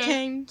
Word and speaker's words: Kane: If you all voted for the officer Kang. Okay. – Kane. Like Cain and Abel.